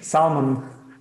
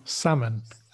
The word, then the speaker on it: salmon: Salmon. The L, I'm going to say, is not there at all. It's barely there, if anything. Salmon. – Salmon. Salmon. – Yes. So the silent letter there is the L.